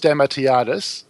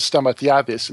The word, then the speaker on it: Stamatiadis: There's two ways of doing it. I do there's the the English-speaking one, we go, it's Stamatiadis. People can understand that. Then you have the proper Greek pronunciation, which is Stamatiadis, and people go, why? What? There's no D in it, and there's all these weird sounds in it. So you go with the English one, it's just pronounce out the syllables.